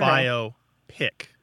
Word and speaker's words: biopic: So it divides, the syllables divide between the O and the P. So it's okay. Biopic.